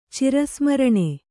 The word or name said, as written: ಚಿರಸ್ಮರಣೆ